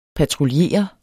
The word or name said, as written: patruljere